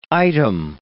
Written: item

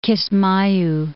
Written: Kismayu

